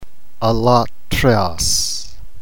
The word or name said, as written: allotrios